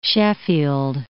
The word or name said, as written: Sheffield